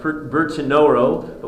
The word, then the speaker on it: Bertinoro: Actually, the city's called Bertinoro, but we often change the pronunciation of cities that have their association with idolatry. So we go from Bertinoro to Bertinura.